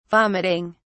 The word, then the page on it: vomiting: British English